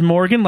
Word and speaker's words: Morgan: My first name is Morgan, like Morgan Freeman.